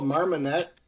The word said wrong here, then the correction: Marmite: Marmite. – Yeah, it's spelled Marmite.